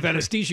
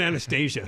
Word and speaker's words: anesthesia: You pronounced it Anastasia. And I was like, oh, is she involved in putting people to sleep? Instead of anesthesia, you said Anastasia.